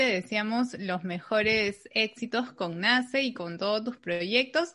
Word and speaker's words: Nasce: Y el estudio se llama Nace Yoga y Nace se escribe SC.